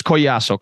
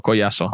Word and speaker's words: Collazo: it's Collazo. Collazo. – We always want to pronounce the L.